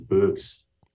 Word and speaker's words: Beuckx: So, my last name is Beuckx. So the first name was pronounced correctly. The last name is Beuckx, but that's not another problem. So only the Dutch people can pronounce it correctly. So the French part of Belgium, they cannot pronounce it either.